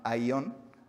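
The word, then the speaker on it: aion (αἰών): Y el vocablo griego utilizado en el versículo 19 para eh, la palabra mundo es un vocablo que se llama aion, A y O acento en la O N, que significa pasajero, efímero y de las modas culturales humanas.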